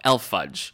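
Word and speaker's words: E.L. Fudge: It's either E, L, and then fudge, or you just pronounce it all, Elf fudge. – Right. – Even though there's only one F.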